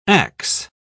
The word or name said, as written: ax